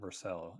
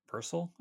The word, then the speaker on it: Vercel: It's not Vercel. It's pronounced Vercel, I think.